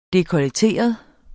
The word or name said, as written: DEKOLLETERET